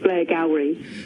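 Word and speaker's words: Blairgowrie: It just says Blair Gowrie.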